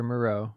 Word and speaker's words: Moreau: Delivers a dime to their tight end and Foster Moreau.